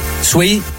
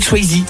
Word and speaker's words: Swayze: Toi, tu dis Patrick Swayze. – Ah, je dis Patrick Swayze. – Voilà. – Parce que le E, c'est I en anglais.